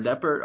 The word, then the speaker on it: Deppert: I'm at Aaron Deppert. I'll spell it this time. This time. It's A-R-O-N-D-E-P-P-E-R-T.